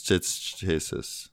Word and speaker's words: chassis: You're actually saying it wrong, too, so... – So, chassis? – Yeah, it's chassis, so... the beginning has a little, like, SH also. It's like a CH mixed with an SH.